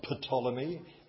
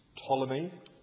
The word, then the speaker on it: Ptolemy: The P is silent. It's not Ptolemy. It's Ptolemy.